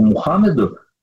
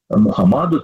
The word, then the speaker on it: Мухаммад: И даже в Коране, при всем уважении к пророку Мухаммеду, Мухаммаду, там по-разному произносится это имя, тем не менее не написано «иди путем Мухаммада», а написано «иди путем Исы».